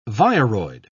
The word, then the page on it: viroid: British English